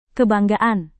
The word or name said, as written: kebanggaan